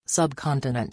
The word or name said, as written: subcontinent